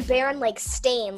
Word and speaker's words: Berenstain: It's not the Berenstain. Like, it's not like Albert Einstein. It's the Baron, like, stain. Like, a stain on your shirt.